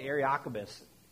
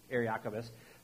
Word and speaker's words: Areopagus: Paul goes up to what's called the Areopagus. I didn't pronounce that right, Areopagus.